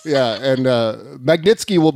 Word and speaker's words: Magnitsky: Majinsky Act meeting. Magnitsky. See, now you got me saying it wrong.